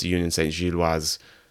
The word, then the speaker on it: Union Saint-Gilloise: Those of you that have listened to this show in the past know that I am a huge Union Saint-Gilloise fan as of, I don't know, a couple months ago. So much so that I realize that I'm saying it wrong. I know that it's Union Saint-Gilloise, but I don't know, habit is hard to break.